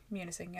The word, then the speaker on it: Munising: So, a group of, how do you say it, Munising? – Munising, yeah.